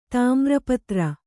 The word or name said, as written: ಧೂಮ್ರಪತ್ರ